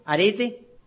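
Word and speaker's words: arete (ἀρετή): Aquí la palabra virtud viene del griego arete, con acento en la primera E, arete, que significa excelencia, excelencia, excelencia de obras, trabajo, esfuerzo, tesón.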